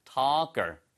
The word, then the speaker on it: talker: 예, 그래서 smooth, the 발음 살리시고 talker, L이 묵음입니다. 예, 토커 이렇게 하지 마시고요. talker, 그렇죠?